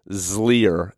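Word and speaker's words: Xlear: But even biofilms, like, there are products like Zlier is how I would pronounce it, but it's X. It's like the word clear with an X.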